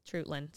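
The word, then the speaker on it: Treutlen: We've also got Long County at Trulton County. – Trutland. – See, yeah. I butcher that every time.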